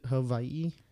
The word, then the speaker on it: Hawaii: So do you get offended when people don't pronounce it Hawaii? – No. I mean, sometimes I catch myself saying Hawaii, you know. – Mm-hmm. – But, like, I already know I'd get lectured like, it's Hawaii. Say it correctly.